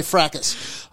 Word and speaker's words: fracas: Did you say fracas or fracas? Fracas. I'm not big on the silent letter. Fuck it. If it's meant to be silent, get it out of there. Okay? Nougat. I say nougat and I say fracas.